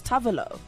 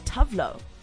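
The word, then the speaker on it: Tove Lo: Seriously, she said in an interview that it's actually pronounced Tavolo and not Tavlo.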